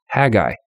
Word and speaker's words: Haggai: I said, how do you say that? And they're like, oh, Haggai.